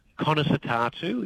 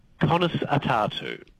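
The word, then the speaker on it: Conas atá tú: Conas atátu is how you say that in Irish. – Conas, say that again. – Conas atátu.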